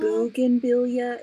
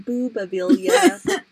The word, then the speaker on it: bougainvillea: Bougainvillea. It's not boobavillea.